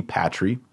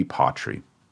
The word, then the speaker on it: patri: Now, we know that, as we say over and over, the accent is on the second to last syllable, so it's not going to be patri. It's going to be patri.